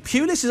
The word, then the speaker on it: Pulis: Now, West Bromwich Albion's new head coach, Tony Pulis, am I saying that right? – That's good.